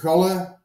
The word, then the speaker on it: Galle: My name is Jonas Galle, but I'm fine with Jonas Galle or whatever, and that's been easy to pronounce for you.